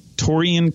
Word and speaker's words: Taurean: Taurean Prince.